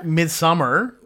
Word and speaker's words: Midsommar: Speaking of scary things, though, Midsommar? Summer? – Midsommar. – That's how they say it in Sweden.